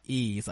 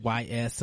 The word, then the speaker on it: Ys: and I didn't know that it was called Ease. I used to call it YS and YS12.